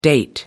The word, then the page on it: date back: American English